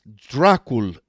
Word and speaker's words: Dracul: Upon his induction, Basarab took the name Dragon, pronounced Dracul in Romanian.